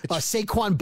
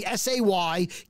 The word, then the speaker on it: Saquon: I write it phonetically so that he could see Saykwan, Saquon. – Because the most important thing is pronouncing it right on the air. – Right, and I'll put Say in capitals so he knows the accent Saquan. – Right. – Right. Saquon or is Saq... – Yeah. – U-A-N.